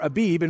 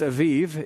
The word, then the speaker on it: Aviv: He gives us the month of Aviv, or Abib in verse 4. It's Aviv, if you want to pronounce it somewhat correctly. I'm sure my Hebrew pronunciation is not totally accurate, but today in the month of Aviv, you are going out.